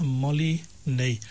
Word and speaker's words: Molinet: A Molinet. Molinet, it's spelled M-O-L-I-N-E-T. As you said, it's French, so it's got that French pronunciation.